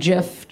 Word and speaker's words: GIF: like, but the whole time I was just like, GIF, GIF, GIF. – Ugh. – We're going to fight about that till the end. – It's a hard G.